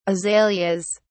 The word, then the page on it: azaleas: British English